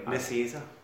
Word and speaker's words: Nicieza: Nicieza.